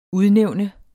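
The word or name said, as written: UDNÆVNES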